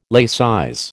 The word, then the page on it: laicize: American English